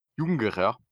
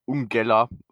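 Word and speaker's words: Jungera (ユンゲラー): In Japan wird es Jungera oder Ungella ausgesprochen, doch es ist vor allem die Schreibweise, die interessant ist.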